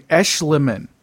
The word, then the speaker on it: Eschleman: I got to start pronouncing Eschleman. That's how you pronounce that. Eschleman. – Eschleman. – Because it's like M.C. Escher.